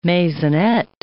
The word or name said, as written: maisonette